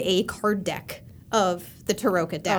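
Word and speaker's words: Tarokka: It's definitely Taroka. – Yeah, was Taroka. How we pronounced it in the office when we were doing it all.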